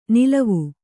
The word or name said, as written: ನಲಾವು